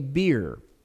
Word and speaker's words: bier: is called a bier. Okay, B-I-E-R. This word has the same pronunciation as pier. In fact, it's spelled the same way. Pier is P-I-E-R. Bier is B-I-E-R, and it's the same pronunciation.